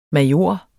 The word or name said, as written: major